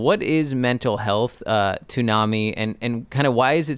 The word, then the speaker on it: NAMI: So we pronounce it NAMI. – NAMI. Okay. – We've been saying it wrong.